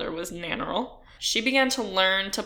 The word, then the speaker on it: Nannerl: Anyway, her name was Maria Anna, although she was nicknamed Nannerl. Nannerl? I'm not sure how you say it. I looked it up, and it said that you put the emphasis on the first syllable, which would make it...